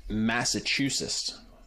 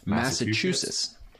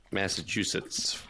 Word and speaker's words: Massachusetts: Massachusetts. I said that wrong. Massachusetts. Yeah. – Massachusetts.